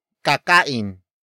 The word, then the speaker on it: kakain: Kakain sila sa labas.